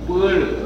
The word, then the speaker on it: 般若: 你要这个般若要读般若，般，也就这个般字，若这个绕字啊，就读成一个惹字，绕紧去要加个心字，啊，般若，记得啊。